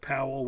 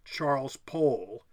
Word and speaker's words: Powell: There was like, I just was looking earlier, there's a family, I think, Powell, and one pronounces it Powell, one pronounces it Paul, and they're brothers. Here it is, Charles and Jonathan Powell. They're British MPs. Charles pronounced it rhyming with toll, so it's Charles Paul. Jonathan pronounces Powell rhyming with towel.